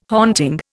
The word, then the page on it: haunting: Indian